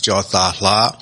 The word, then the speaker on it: Kyaw: Burmese, actually, yes. Okay. And actually, it's pronounced Jala. So in Burma, we don't have first names and last names. Okay. We just have a name. And my name happened to have three syllables. So coming to the States, you have to have first, last, and middle. So three syllables would divide into three.